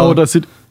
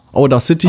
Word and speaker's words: Audacity: Audi, nee, Audio City heißt das. Das ist ja wirklich Auda. Auda City, Auda. Audacity.